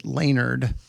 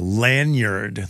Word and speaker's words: lanyard: But she also pronounces it Laynard. So that's an old one as well. Lanyard that you wear around your neck.